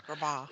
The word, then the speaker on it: Agrabah: I guess I'm saying it wrong. – Agrabah is on my list, and right now it still says temporarily unavailable, but hopefully that will not be for much longer.